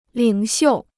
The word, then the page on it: 领袖: lǐng xiù